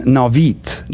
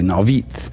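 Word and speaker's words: Naioth: And he said to Samuel went and stayed in Navith. Now, I know it doesn't look like it would say that, but that's the way the pronunciation guide calls it. So we'll call it that way, Navith.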